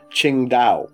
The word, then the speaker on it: Tsingtao: There's a Chinese beer called Qingdao. – Yep. – And it's actually spelt T-S-I-N-G-T-A-O. – Ah. – Qingdao.